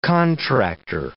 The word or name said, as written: contractor